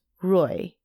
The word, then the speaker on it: Rui: And so the phonetic way that it's spelled in Chinese, the Ray, so Han is still spelled H-A-N. Ray is actually spelled R-U-I. So it's Roy.